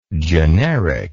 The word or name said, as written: genericness